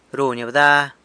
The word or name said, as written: runy bda